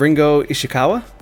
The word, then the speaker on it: Ringo Ishikawa: Can you tell me a little bit about The Friends of Ringo Ishikawa? – Good pronunciation.